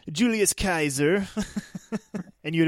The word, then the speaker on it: Caesar: Apparently, you pronounce his name Kaiser, not Caesar. – Really? That's interesting. That's sounds German. But yeah, but the C in Latin, you pronounce it like a hard K sound, like K, so it's Kaiser.